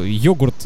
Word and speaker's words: йогурт: Я говорю, все, йогурт, в слове йогурт ударение на О ставится.